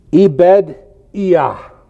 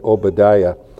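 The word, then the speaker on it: Ebed-Iah (עֹבַדְיָה): This guy is Ebed-Iah. And that's actually how you pronounce this word.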